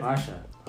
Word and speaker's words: Pasia: So, Eric, Pasha. Pasha, right? – Pasha. – Pasha. – I know you for how long, and you still can't pronounce my last name correctly.